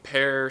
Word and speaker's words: Per: No, it's hosted by a guy named Pear something. – Pear. – Perry? He's German. – Oh, okay. – It's just P-E-R, pronounced pear, the fruit.